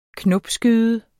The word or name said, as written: knopskyde